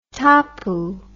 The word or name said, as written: tapu